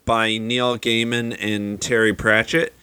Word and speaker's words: Gaiman: Uh, it's Neil Gaiman.